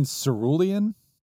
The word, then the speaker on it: cerulean: 8 seed Sayulene versus Indigo. – Spell that. Well, Matt's thinking. – Ceruleen. – Saline against. – Oh, you mean cerulean? – Cerulean. Maybe that's how you say it.